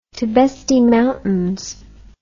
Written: Tibesti Mountains

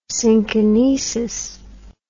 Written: synkinesis

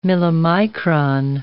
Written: millimicron

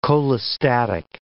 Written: cholestatic

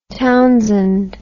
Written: townsend

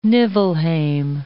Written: Niflheim

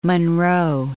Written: Monroe

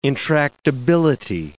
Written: intractability

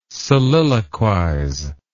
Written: soliloquize

